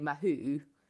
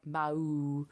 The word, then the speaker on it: Mahut: Um, I mean, yeah, he's slightly more difficult than Nicolas Mahut, but I mean, a lot of people would say Mahou, but I think it's more like Mahou.